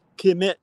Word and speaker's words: Kemet: and then we have KMT when you add the T, which is pronounced Kemet 2,